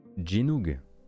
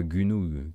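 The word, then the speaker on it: Gynoug: vous pourrez vous diriger vers Jinoug. évidemment et non pas Gunug, Qui prononce Gunoug il, y, a un mec sur Twitch la semaine dernière, il a dit Gunoug.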